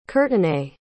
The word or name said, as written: courtenay